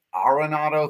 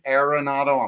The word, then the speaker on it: Arenado: it is technically pronounced Arenado. If you say Arenado, I'm not going to correct you, but now that I'm have four gold gloves, it is fun.